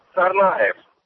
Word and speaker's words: Tsarnaev: like Tsar, like a Russian Tsar, right. – Aev. So this is what he would sound like. – Johar Tsarnaev.